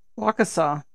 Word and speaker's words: Waukesha: Waukesha.